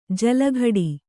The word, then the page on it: ಜಲಘಡಿ: jala ghaḍi; kannada